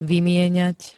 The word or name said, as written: vymieňať